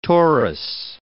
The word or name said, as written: torus